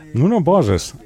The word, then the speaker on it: Nuno Borges: Nuno Borges. Haben wir gelernt. Haben wir von unserem, ich glaube, portugiesischen Kollegen hier gelernt, dass er so ausgesprochen wird.